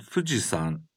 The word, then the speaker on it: Fuji-san: Is the san in Fuji-san the same as the honorific title attached to people's names? – No, it's not. Although its pronunciation is the same, a mountain is yama, but it is pronounced as san when attached to the names of some mountains.